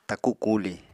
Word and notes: taku kuli: Apatani

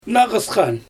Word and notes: naGysxan: Xyzyl

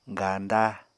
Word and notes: ganda: apatani

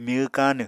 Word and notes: myw káanw: Apatani

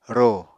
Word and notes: rò: Apatani

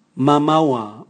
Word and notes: mamaua: Raga

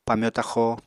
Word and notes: pamyo-taxo: Apatani

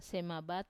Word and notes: semabat: Wamesa

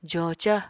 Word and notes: joca: apatani

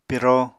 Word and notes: piro: Apatani

